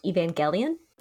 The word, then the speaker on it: Evangelion: First things first, it's pronounced Evangelion.